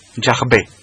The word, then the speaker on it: Yahvé: Porque todo apunta, como tú has dicho, Isaskun, todo apunta que la pronunciación más correcta sería Yahvé, o Yahvé, que decimos en castellano, Yahvé, Yahvé.